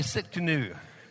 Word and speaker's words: Tsidkenu: Everybody say Tsidkenu. The T is silent on this.